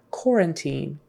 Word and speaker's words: quarantine: The U is silent and the AR is kind of pronounced like an OR. Quarantine.